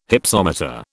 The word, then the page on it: Hypsometer: British English